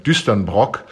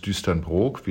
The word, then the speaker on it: Düsternbrook: Der Anteil des Autobiografischen, wie beim ersten Buch Düsternbrook, viele sagen Düsternbrock, aber in der Tat heißt es Düsternbrook, wie Norddeutsche das aussprechen, Düsternbrook.